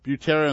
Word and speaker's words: Butera: That was a record called Land of a Thousand Dancers, all twisting edition, and that was Louis Prima with the late Sam, how'd you pronounce it? – Butera and the Witnesses doing Twist All Night, 1962.